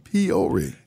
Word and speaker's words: Peoria: Peoria. You have to say it right. You have to say it like Richard Pryor.